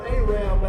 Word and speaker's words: Arab: Yes, there is a place called Arab, Alabama. And that is how it's pronounced.